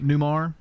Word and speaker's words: Newmar: How do you say Julie? Is it Newmar? – Newmar? – Mm-hmm. – Okay.